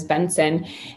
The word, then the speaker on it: Bengtzen: And I only said that for Brian, because when we go to my last name is Benson and it's spelled ridiculous.